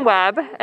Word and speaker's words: Webb: My name is Ellyn Webb, and it's spelled E L L Y N W E B B.